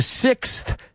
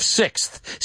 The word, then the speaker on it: sixth: Now, I want to look at the pronunciation of sixth. Sexto. Right? Now, if you're British, you say sixth. Well, at least my British friends. So, es enfermo con sonido Z. Sixth. Sixth. And if you're American, you say enfermos con TH o Z.